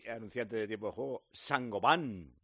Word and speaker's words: Saint-Gobain: Bienvenidos, bienvenidos, Sangoban. – Sangoban. – Lo vas a ver escrito Saint-Gobain, porque vienen de Francia, pero se pronuncia Sangoban.